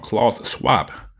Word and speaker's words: Klaus Schwab: I'm saying his name wrong. Klaus Schwab.